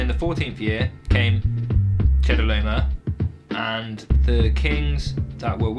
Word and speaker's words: Chedorlaomer: Yeah, I think Chedorlaomer. – I think you got it first time, for sure, but that's a confusing word.